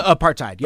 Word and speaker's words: apartheid: You know, just in terms of, like, you know, classism and things like District 9 just basically South Africa apost, or however you pronounce it? – Apartheid, yeah. – Apartheid, yeah.